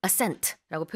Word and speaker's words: ascent: 또 지금 얘기하신 것처럼 이제 비행기가 고도를 높여서 올라가는 것도 ascent라고 표현할 수 있는데 스펠링이요. A-S-C-E-N-T. 강세는 뒤에 있죠. ascent. 반대로 하강, 내려가는 것은 뭐죠? – descent. – descent. – Yeah. – 이때는 스펠링이 D-E-S-C-E-N-T.